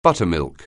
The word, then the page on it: buttermilk: anglais